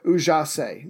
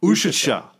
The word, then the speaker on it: Užice: It's I got the pronunciation Užice. Užice. It's probably wrong, but that's the pronunciation I heard.